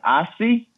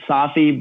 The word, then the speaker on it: Safi: Yeah, so in French, in Arabic, it's pronounced Asfi, but in French, they say Safi. So we call it Safi, but I mean, some people call it Asfi.